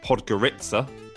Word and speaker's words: Podgorica: Uh, the capital of Montenegro having been through it myself, I can tell you it's called Podgorica, not whatever Sam called it.